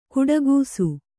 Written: ಘಟಾಕಾಶ

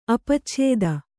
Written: ಅಪಚ್ಛೇದ

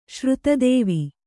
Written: ಶ್ರುತದೇವಿ

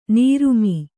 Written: ನರಮು

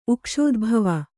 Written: ಉಕ್ಷೋದ್ಭವ

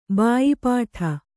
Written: ಭಯಪಡು

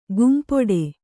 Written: ಕೆಂಪಿಡಿ

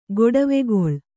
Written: ಗೊಡವೆಗೊಳ್

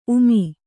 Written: ಊಮೆ

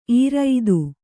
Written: ಈರಿತ

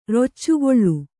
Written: ರೊಚ್ಚುಗೊಳ್ಳು

